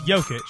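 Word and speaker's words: Jokić: Man, Jokic, Joke, how do you say his last name? Jokic? Jokic? Great start. It's Jokic. Jokic.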